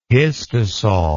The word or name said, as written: histosol